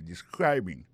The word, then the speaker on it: describing: Ça nous carré là, ça nous constater là, nous allons constater que il a prononcé mot de describing, accent tombé sur deuxième syllabe là. Ça veut dire mot ça a trois syllabes describing.